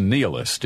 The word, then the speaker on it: nihilist: I think it's nihilist is the way most people pronounce that. It is spelled N-I-H-I-L-I-S-T.